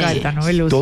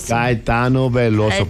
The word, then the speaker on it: Caetano Veloso: ¿Cómo se dice este? Caetano Veloso. – Veloso. – Mejor dicho, sí. Caetano Veloso. – Todo lo que sea portugués. – Caetano Veloso.